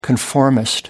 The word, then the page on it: conformist: American English